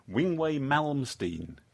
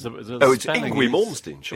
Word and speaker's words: Yngwie Malmsteen: Wingway Malmsteen. – It's Yngwie Malmsteen. – Is it Yngwie? – Yngwie Malmsteen. – Oh, okay.